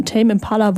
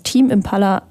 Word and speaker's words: Tame Impala: Wir hören Dracula von Tame Impala, wobei manch einer würde das Ganze ja auch als Team Impala aussprechen.